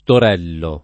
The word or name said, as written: Torello